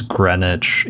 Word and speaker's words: Greenwich: Yeah, I mean, the greatest travesty from that is that they force us to pronounce it as Greenwich, even though there's a W in that word, and I will never get over that.